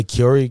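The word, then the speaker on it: Kouri: It's K-O-U-R-I. Curie Richens.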